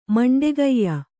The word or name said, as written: ಮಂಡೆಗೈಯ